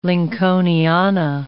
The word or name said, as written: Lincolniana